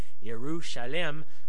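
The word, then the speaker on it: Yerushalem (ירושלים): the Hebrew pronunciation of Jerusalem is Yerushalem, which the Yeru is, they think, is from Jireh, again, and then Shalem, which means, of course, we know as peace.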